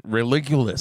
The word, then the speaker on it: Religulous: Religious? Am I saying it correctly? – That's Bill Maher's Religulous. – Religulous, sorry. – Religulous. There we go.